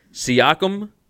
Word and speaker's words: Siakam: Siakam for the Raptors? – Yep.